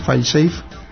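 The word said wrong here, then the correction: fail-safe: File safe, o cómo se pronuncia. – Fail safe. – Fail safe. Bueno, pues fail safe.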